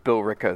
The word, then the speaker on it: Billerica: How is it spelled? – Billerica. But you leave out syllables.